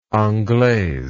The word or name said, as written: anglaise